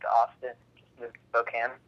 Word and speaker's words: Spokane: Yeah, I don't think it rhymes with cocaine. I'm pretty sure Spokane is right.